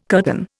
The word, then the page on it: curtain: Indian